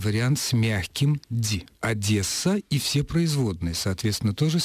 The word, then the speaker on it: Одесса: Поэтому вы, Светлана, и все ваше окружение, как вы пишете, не допускало ошибок, когда произносила, да и продолжает произносить «Одесса» с твердым «д».